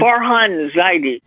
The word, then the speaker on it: Farhan Zaidi: Farhan Zaidi.